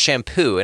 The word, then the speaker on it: Champoux: His last name is spelled C H A M P O U X. Okay, and so, yeah, thanks, right? And so and he's a sweetheart Sorry. of a guy. – No, he's amazing. Like a sweetheart of a guy. And but it's pronounced shampoo and I'm just like, okay, you know what I mean?